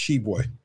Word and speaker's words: Tshiebwe: I think is, the T is silent for what it's. I think it's just Tshiebwe.